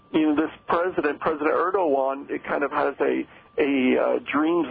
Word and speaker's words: Erdogan: President Erdogan, I believe is how you pronounce it. You know, Erdogan.